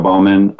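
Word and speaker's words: Bohman: Anybody wants to spell his last name, it's B-O-H-M-A-N. We've got a silent H in there.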